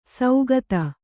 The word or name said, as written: ಸೌಗತ